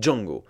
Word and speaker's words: Django: Et en version française, de même, pendant tout le film, on l'appelle Django. Pourquoi tout simplement parce que la langue anglaise veut que les mots commençant par J se prononcent J.